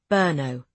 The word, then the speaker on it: Brno: He pronounced it Brno.